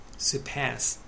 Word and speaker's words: Surpass: Words and phrases、 Figure、数字、 Revenue、収益、収入、 Casino、カジノ、賭博場、 Gambling、ギャンブル、賭博、 Surpass、アクセント後ろですね、上回る、超える、 forecast、これは名詞と動詞があります。